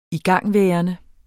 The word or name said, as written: igangværende